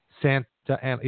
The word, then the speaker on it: Santa Ana: But, you know, Nam was in Anaheim, and Anaheim is right next to Santa Ana. Santa Ana. Yeah, I'm saying that right.